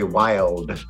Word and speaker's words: Wilde: I even bought a book called The Great Comedians by Larry Wilde, W-I-L-D with a silent E, The Great Comedian.